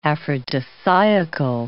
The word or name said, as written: aphrodisiacal